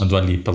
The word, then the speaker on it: Dua Lipa: A Dua Lipa, ela tem uma voz muito peculiar, eu gosto muito. Você deu uma pesquisada aí? Falei certo? – Tá é, tá certo? Falou. Eu é, não lembro qual que é, é o...